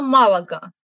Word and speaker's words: Малага: Ну и Малага, да, то же самое. Правильно, Малага. И я надеюсь, что в русских словарях будет Малага тоже.